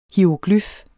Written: hieroglyf

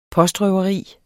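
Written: POSTRØVERI

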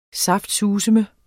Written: saftsuseme

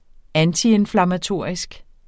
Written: antiinflammatorisk

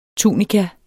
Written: TUNIKAS

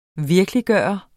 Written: VIRKELIGGJORTE

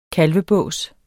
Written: kalvebås

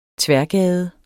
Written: tværgade

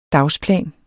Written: dagsplan

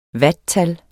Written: watttal

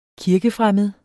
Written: kirkefremmed

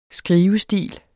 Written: skrivestil

